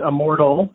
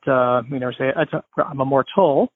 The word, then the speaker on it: Immortal: It is pronounced Immortal, as you phonetically say it. So it's not, I'm immortal.